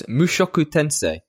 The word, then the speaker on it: Mushoku Tensei: Close. Mushoku Tensei. Jobless Reincarnation. – It wasn't that close, really, when I've got it written in front of me. There are only so many ways you can say that, and I got the three syllables wrong.